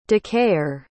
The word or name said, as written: decayer